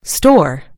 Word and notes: store: American English

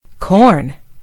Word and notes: corn: British English

